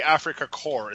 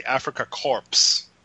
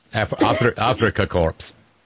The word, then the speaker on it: Afrika Korps: By the way, it's not the Afrika Korps. It's the Afrika Korps. I know. In German, you pronounce all the words. Yeah. I know it sounds weird. – You pronounce it. Yeah, you pronounce all the letters. It ain't French.